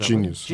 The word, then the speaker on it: Genius: Ee, bağımsız Sanat İnisiyatifi Akademi Genius diye okunuyor Genius.